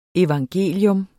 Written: evangelium

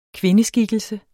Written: kvindeskikkelse

